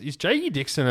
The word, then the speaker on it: Jake Dixon: So, mate, I think is J.E. Dixon a championship contender? I mean, am I saying that correctly? – Yeah, 100%.